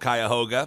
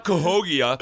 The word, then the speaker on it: Cuyahoga: It's called Cuyahoga. And I was going to be like, no, it's not Cahogia or whatever you were going to say. It's Cuyahoga Falls.